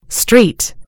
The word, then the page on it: street: American English